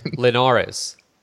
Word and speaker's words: Linares: Okay, so first of all, let's just address the elephant in the room right now, and that is that you just pronounce Linares like you're Harold Letterman. Linares? What? – Wait, how do you say it then? – Linares. – Linares, sorry.